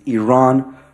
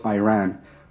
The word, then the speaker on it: Iran: Our country is named Iran, not Iran. Please, once and for all, let's get this clear.